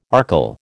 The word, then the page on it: archil: American English